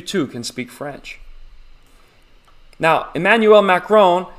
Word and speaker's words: Emmanuel Macron: You have to say it like that. If you're speaking French, you want to show respect. So you got to build up the phlegm. Emmanuel Macron. You make it sound like you have macaroni and cheese stuck in the back of your throat.